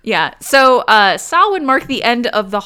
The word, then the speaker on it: Samhain: It's spelled like Samhain, Samhain. And because it's a Celtic word, it's not pronounced at all like it's spelled. It's pronounced Samhain.